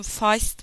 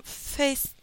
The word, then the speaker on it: Feist: Feist mi demem gerekiyor? Feist diye mi adlandırmam gerekiyor derken hemen Google'dan çok kısa bir araştırma yaptığımda bir BBC radyo şovlardan birine denk gelmiştim. Ve oradaki İngiliz olduğunu düşündüğüm ve inandığım DJ kendisine Feist diye hitap etmişti. O günden beri ben de kendisine Feist diyorum ama Face diyen arkadaşlarımı da duyuyorum.